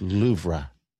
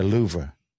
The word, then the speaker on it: Louvre: It's L-O-U-V-R-E, and they pronounce it Louvre. The Louvre. Louvre. It is. The R is just not there.